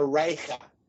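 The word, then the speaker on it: Reiche: And they, I believe there was a woman named Maria Ricci. Well, I know there was a woman named Maria Ricci. And I think the pronunciation, she was German, so it's Maria Reicha.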